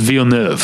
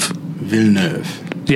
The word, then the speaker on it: Villeneuve: It's two syllables. The first one is veal, like the meat. And the second syllable is in our listener's description is like sort of like pronouncing the word nerve with a British accent, like without the R. So it's Villeneuve.